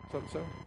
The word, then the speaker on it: sozo: The word is not just S-O-Z-O. Come on, you Greek scholars. How do you pronounce that? So-so? So-so.